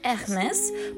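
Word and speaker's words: Hermès: 那一般很多人读英语的时候，他习惯性读成 Hermes，嗯，Hermes 这种。但是我也研究了不少啊，也模仿了不少。它是因为法国的品牌，所以读的时候，尤其是你想描述这个奢侈品品牌的时候，读音应该要注意一下，怎么读呢？Hermes。 Hermes，哎，就是它会有一个呃和之间的区别，就是稍微啊、呃，就那种弱度弱度啊。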